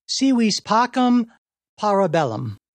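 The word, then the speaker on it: si vis pacem, para bellum: It opened with the phrase, let's see if I get the pronunciation right, si vis pacem, parabellum.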